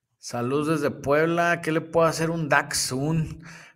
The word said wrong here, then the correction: Datsun: Puedes pronunciar bien su nombre. es Datsun, con de Tito.